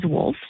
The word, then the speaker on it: Wolfe: And interesting enough, our last name is Wolf, spelled with W-O-L-F-E.